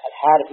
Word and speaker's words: الحارث: لا ابن الحارث، لكن هم مثل الحارث ما يحطون فيها ألف، هذا ينطق بالألف ولا تكتب، مثل هذا أي هذا فيها ألف بين الأبناء والزال، نطقا فيها ألف، لكن كتابة الحاق فيها ألف نطقا لا كتابة.